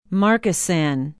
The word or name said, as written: Markesan